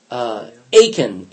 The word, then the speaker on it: Achan: You all remember chapter 7 is a guy named Achan, A-C-H-A-N. And Americans say it all the time. Oh, my aching back. – I know that. I've heard it before. – Oh, my aching back. It's just they pronounce the same.